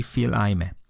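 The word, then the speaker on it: Fils-Aimé: Phil Aime. – Böyle mi okuyoruz? – Nasıl galiba okuyoruz? öyle okunuyor. Regi Phil Aime.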